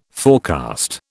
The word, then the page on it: forecast: British English